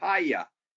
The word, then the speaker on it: hayah: Okay, that word was in Genesis chapter 1, verse 2 is the Hebrew word haya, H-A-Y-A-H, if we're spelling it phonetically.